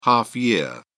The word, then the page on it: half year: British English